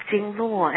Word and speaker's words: Jingluo: Did I pronounce it right? Jingluo? – More like it's Jingluo.